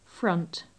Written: front

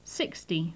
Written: sixty

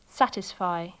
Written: satisfy

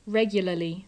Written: regularly

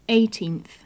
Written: eighteenth

